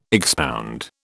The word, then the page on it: expound: British English